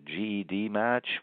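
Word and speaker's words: GEDmatch: Is it GED Match? Is it GED Match? What's the name of the genealogy company? – I think you can go with either one.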